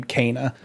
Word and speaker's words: Kena: Kena. – Kana? Fuck you, God. – Yeah, in the game, she pronounces her name Kana.